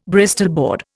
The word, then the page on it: Bristol board: Indian